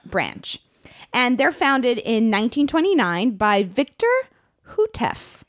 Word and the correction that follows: Houteff: Now, I'm definitely saying that incorrectly. Matt, it's spelled H-O-U-T-E-F-F. – Sounds right to me. – Hootef.